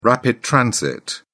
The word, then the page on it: rapid transit: British English